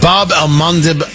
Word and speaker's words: Bab el-Mandeb: This is the Bab el-Mandeb Strait, B-A-B, and then the next word is A-L-M-A-N-D-E-B. It's pronounced a couple of different ways. The Bab el-Mandeb Strait.